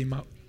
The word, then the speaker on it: Emmaus: How do you say Emmaus? Emmaus. Yeah, nothing to do with Emmaus. In Portuguese, we say Emmaus, but Emmaus is so much better.